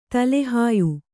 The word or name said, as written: ತಲೆಹಾಯು